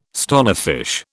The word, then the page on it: stonefish: British English